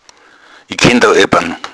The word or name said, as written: ebban